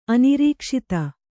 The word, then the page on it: ಅನಿರೀಕ್ಷಿತ: anirīkṣita